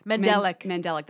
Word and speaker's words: mandelic: Mandolin. – Yeah, those are all types of... – Is that how you say it? Mandelic. – Mandelic.